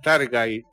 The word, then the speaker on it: Targaryen: La Casa del Dragón centra su mirada en la familia de los Targaryen. Targaryen. – ¿Cuál es? ¿Eso es? – La misma a la que pertenecía Daenerys. – Ah, ok. ¿Eh? Ah, los Targaryen serán. – Targaryen.